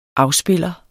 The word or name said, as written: afspiller